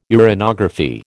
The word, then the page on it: uranography: American English